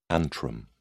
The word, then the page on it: antrum: British English